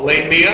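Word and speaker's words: Lamia: It's called Lae Mia, and I'm told that is the correct pronunciation.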